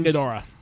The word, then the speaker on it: Ghidorah: Ghidorah? – Yeah, King Ghidorah.